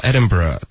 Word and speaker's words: Edinburgh: 然后 Edinburgh 大家的这个发音也就是在尊重当地苏格兰人他们的发音、也就是 Burr 啊，这也是个文化点了、感谢这位同学的提问、开始我也没有搞清楚，我还以为这个拼错了呢。